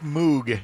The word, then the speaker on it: Moog: It's Moog. Rhymes with Vogue. – Even though it looks like Moog, it's Moog.